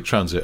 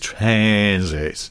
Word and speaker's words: Transit: Classic Transit. Although I realise I've just said it wrong. Of course, it is Transit.